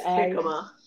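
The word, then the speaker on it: jicama: Pineapple, and Yikama, is that how you say it? – Jikama with an H. J is pronounced H.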